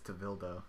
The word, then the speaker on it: Tevildo: I might pronounce this wrong. I will pronounce it wrong. Tevildu? Tevildu? – I think it's Tevildo.